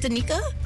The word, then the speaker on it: Danica: It's D-A-N-I-C-A. I don't know if she's Danica or Danica. I always called her Danica McKellar. – Well, I think Danica. I have a friend named Danica who's pronounced Danica. But no matter where you're from, you can pronounce it Danica.